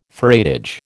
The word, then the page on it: freightage: American English